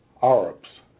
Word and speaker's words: Arab: And when I mention Arabs, I'm conscious of the fact that many African Americans don't like the Arabs and they call them Arabs. But the actual correct pronunciation is Arab.